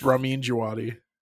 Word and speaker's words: Ramin Djawadi: It's Ramin Djawadi.